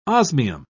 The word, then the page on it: osmium: British English